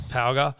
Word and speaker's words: Ponga: First, I'm going Palga or Ponga. I'm not sure how it's pronounced. – Palga.